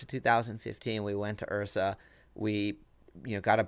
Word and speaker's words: IHRSA: around January, I told Monica that we needed to go to IHRSA, which IHRSA, I-H-R-S-A, it's spelled a little funny, is the most prominent sort of trade show in the fitness space.